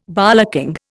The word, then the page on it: bollocking: Indian